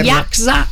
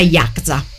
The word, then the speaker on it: Yakuza: La Yakza è la Yakuza che noi intendiamo, ma è pronunciata in maniera distorta. la pronuncia giusta è Yakuza, quindi quella setta giapponese che si occupa della malavita.